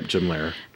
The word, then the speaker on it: Jim Lehrer: I'm sure you saw on Twitter that people were like losing their minds over the moderator, Jim Lehrer or whatever. Is that who you say his name? – Yep, Jim Lehrer.